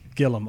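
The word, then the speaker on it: Gilliam: Is it Gillum or Gillum? Am I pronouncing it right? – I'd say Terry Gillum. – Gillum, okay.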